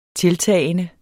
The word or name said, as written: tiltagende